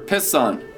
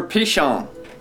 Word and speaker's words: Pishon: It's the river Pison. And don't even try to pronounce it right, because in Hebrew it's completely different anyway. It's river Pishon.